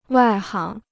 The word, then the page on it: 外行: wài háng